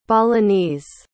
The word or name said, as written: balinese